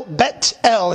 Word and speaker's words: Bethel: The word Bethel, Bet-El is the actual pronunciation.